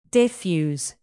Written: diffuse

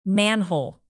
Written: manhole

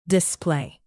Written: display

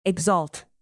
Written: exalt

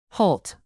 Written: halt

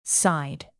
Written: scythe

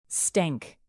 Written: stank

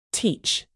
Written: teach

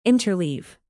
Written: interleave

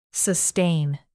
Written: sustain

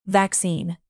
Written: vaccine

